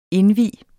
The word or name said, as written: indvie